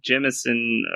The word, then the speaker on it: Jemisin: Reading N.K. Jemisin, or however you say her name. – I think you got it.